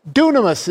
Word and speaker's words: dunamis: Dunamis is how it's pronounced in the Greek.